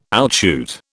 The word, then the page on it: outshoot: British English